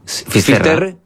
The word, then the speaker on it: Finisterre: Entonces, llegó a Finisterre. ¿Se dice así? – Finisterre. – Es que vamos a poner los gallegos también, en fin.